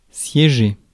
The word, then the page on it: meet: /miːt/; British English; American English; [miːt]; /ˈmiːtʰ/